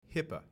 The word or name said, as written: HIPAA